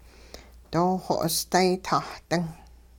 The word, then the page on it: dooxo'osday-tah-ding: hupa